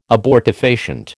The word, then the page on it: abortifacient: American English